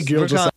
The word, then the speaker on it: Gilgeous: I've heard it pronounced Shai, but it's Gilgeous. It's Shai Gilgeous.